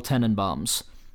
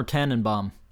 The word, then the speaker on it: Tenenbaums: Even though I liked it, I liked the next movie even better, and the next movie is The Royal Tenenbaums. Is it pronounced Tenenbaum or Tannenbaum? I think either is appropriate.